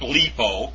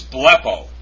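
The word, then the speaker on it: blepo (βλέπω): It's not bleepo, it's blepo.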